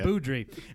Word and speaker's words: Beaudry: Do you have any idea how to say his name? – No. It's always been Beaudry.